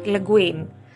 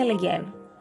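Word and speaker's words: Le Guin: Ο Έολος έγραψε Ούρσουλα Λεγκέν και όχι Λεγκουίν, που ξέρω ότι και η ίδια, επειδή ζούσε στην Αμερική, έλεγε το επίθετό της, του άντρα της Λεγκουίν, αλλά είναι Λεγκέν. Είναι γαλλικό και σας παρακαλώ να το προφέρετε σωστά και στην Ούρσουλα, ζωή σε λόγο μας εκεί που είναι, ε, δεν μπορώ το Λεγκουίν. Λεγκέν διαβάζεται. Είναι 298 σελίδες όπως πάντα από ό,τι βλέπω η αγαπητή Ούρσουλα δεν θέλει να κουράσει τον αναγνώστη της.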